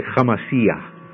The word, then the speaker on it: HaMashiach: Or actually, they pronounce it a little different. They'd say Hamashiach, but they would say Messiah.